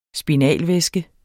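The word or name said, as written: spinalvæske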